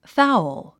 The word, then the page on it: fowl: fául; ファウル